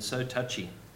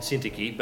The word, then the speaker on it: Syntyche: I can remember years ago one of our senior female leaders in the church I was converted in used to refer to them as odious and so touchy. But I think it's actually pronounced Euodia and Syntyche, but they were obviously having some difference and Paul wanted them to actually work better together.